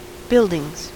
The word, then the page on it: buildings: American English